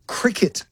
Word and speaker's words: Cricut: There's a thing called a cricket maker, which I haven't been able to fathom. I've never heard of it. It's spelled C-R-I-C-U-T, and it's apparently to custom design T-shirts, cards, stickers, and a whole bunch of other things.